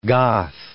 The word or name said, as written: Goth